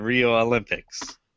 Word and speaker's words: Olympic: Starting at number 10, we have Mario and Sonic at the Rio Olympics. – No S. – Oh, yeah. – Rio Olympics. Say it right. – Because we're in Japan. – Yes. – At right. Rio Olympic.